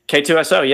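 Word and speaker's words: K-2SO: K2SO, yeah, absolutely.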